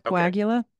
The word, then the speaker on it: coagula: Is that correct? How am I pronouncing it? – Yep, coagula. Okay.